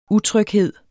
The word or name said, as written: utryghed